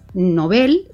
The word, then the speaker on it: Nobel: Respetando la pronunciación sueca del apellido de Alfred Nobel, ¿verdad? – Nobel. – Aunque está muy extendido quienes lo pronuncian Nobel, uh-huh, pero se recomienda, de hecho se escribe sin tilde, ¿verdad? Precisamente, uh-huh, por eso, porque es palabra aguda terminada en L.